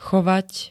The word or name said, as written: chovať